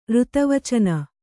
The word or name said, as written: ಋತವಚನ